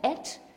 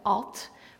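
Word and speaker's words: et (את): En in het Hebreeuws spreek je het uit et, maar wij zouden zeggen at, want het bestaat namelijk uit de alef, de a, en de taf, en dat is de laatste letter van het alefbet.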